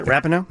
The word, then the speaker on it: Rapinoe: Is that how you pronounce that? Rapinoe. – Mm-hmm. Yep.